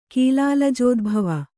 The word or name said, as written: ಕೀಲಾಲಜೋದ್ಭವ